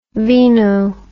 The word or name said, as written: veno